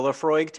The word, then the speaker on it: Laphroaig: And I am drinking the Laphroaig 10 year. – Sorry, I totally just got distracted in our notes. You're drinking the Laphroaig 10 year.